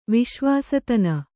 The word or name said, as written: ವಿಶ್ವಾಸತನ